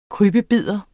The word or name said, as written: krybbebider